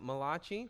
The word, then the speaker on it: Malachi: Contrary to the popular cheesy joke, he was not the Italian prophet of Malachi. He was Malachi, that's how you pronounce it, and he is Hebrew.